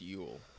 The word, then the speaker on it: Yul: Yul Brynner is with no E. – That it's Yul? – Yeah, but it's pronounced Yule.